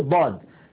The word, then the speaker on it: ضاد: وكما نعلم أيضا بعض الناس يخرجها من مخرج الظاء فيقول الظاد، حرف الظاد، يعني الظاد ما في شيء في اللغة اسمه ظاد، في شيء اسمه ضاد، وهناك حرف آخر سيأتي معنا هو حرف الظاء، فالظاء شيء والضاد شيء آخر.